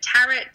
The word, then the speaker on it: tarot: I have heard it tarot, I've heard it tarot, I've heard tarot, like carrot. So I say tarot,